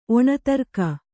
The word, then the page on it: ಒಣದುರ್ಗಿ: Kannada